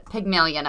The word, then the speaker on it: Pygmalion: There's a very classic study, the Pygmalion effect. And you said it right, the way Google did. – Okay, good.